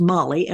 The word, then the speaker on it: Mali: Well, we're pronouncing Molly as Molly, M-O-L-L-Y, and yet it's really spelled M-A-L-I.